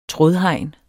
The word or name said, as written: trådhegn